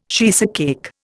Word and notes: cheesecake: Indian

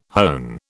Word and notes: hone: British English

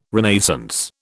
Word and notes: Renaissance: British English

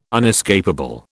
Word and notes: unescapable: British English

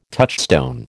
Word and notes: touchstone: American English

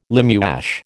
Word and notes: limewash: American English

